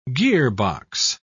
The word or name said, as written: gearbox